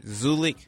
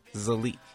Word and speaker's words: Zulek: It's spelled Z-U-L-E-K, and some people pronounce it Zulik, but the way it's pronounced is Zulik.